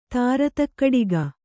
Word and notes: ತಾರತಕ್ಕಡಿಗ: Kannada

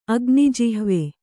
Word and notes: ಅಗ್ನಿಜಿಹ್ವೆ: Kannada

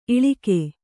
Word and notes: ಈಳಿಗ: īḷiga

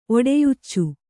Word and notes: ಒಡೆಯುಚ್ಚು: kannada

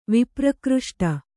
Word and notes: ವಿಪ್ರಕೃಷ್ಟ: Kannada

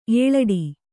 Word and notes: ಏಲಾಡು: Kannada

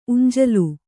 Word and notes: ಉಂಜಲು: uñjalu

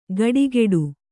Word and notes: ಗಡಿಗೆಡು: gaḍigeḍu; Kannada

